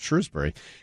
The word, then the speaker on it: Shrewsbury: It's not Shrewsbury. It's Shrewsbury. – Simsbury, Middlebury, Shrewsbury, right, Sudbury. – But it's Shrewsbury. – Bury. – That's how it's pronounced. – Okay. – It's just Shrewsbury.